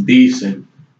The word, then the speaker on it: Dicen: And the name of the song is Dicen. That's spelled D-I-C-E-N.